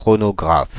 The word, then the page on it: chronographe: IPA: /kʁɔ.nɔ.ɡʁaf/; French